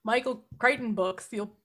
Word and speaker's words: Crichton: it would also say Michael Crichton, and then in brackets, rhymes with frighten.